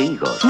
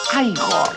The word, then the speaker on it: Igor: Usted debe de ser Igor. – No, se pronuncia Igor.